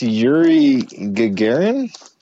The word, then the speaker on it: Yuri Gagarin: You know, I think it's Yuri Gagarin. – Is that how you say it? – Yeah, yeah.